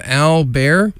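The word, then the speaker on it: Al Bairre: Keeping the tunes going here with a wonderful new track from Al Bear, and it's spelled A-L space B-A-I-R-R-E.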